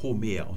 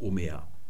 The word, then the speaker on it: Homer: Hier ist der Spiritus nach rechts geöffnet, also sprechen wir ein H. Deswegen haben wir Homer und nicht Omer.